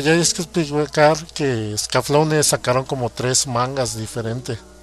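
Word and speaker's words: Escaflowne: Así se pronuncia, Scaflaune.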